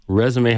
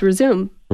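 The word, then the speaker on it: résumé: Resume has an accent on the end. – Versus resume.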